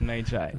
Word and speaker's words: Magi: So I asked some Americans and they told me it's Mei Chai.